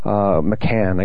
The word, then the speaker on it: McCann: He's very well versed in the disappearance of Madeleine McCain. McCann, I guess is how you pronounce it. I tend to say McCain.